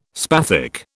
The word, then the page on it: spathic: British English